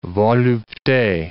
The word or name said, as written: volupte